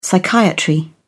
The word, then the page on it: psychiatry: British English